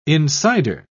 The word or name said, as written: insider